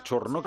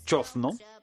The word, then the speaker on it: chozno: Que ha entrado la duda y el uso que yo le doy a la palabra chorno, que además oficialmente no se escribe chorno, se escribe chozno. – Chozno. – Existe chozno. Con Z.